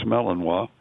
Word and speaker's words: Malinois: So, on this next one, I need your help pronouncing what I think is a dog species, and that is Malinois. – That's good. That's a good. You know how to pronounce that. It's Malinois.